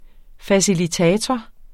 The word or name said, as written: facilitator